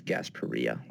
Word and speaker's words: Gasparilla: which is that Gasparilla is actually pronounced Gasparilla, like tortilla.